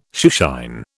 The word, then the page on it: shoeshine: British English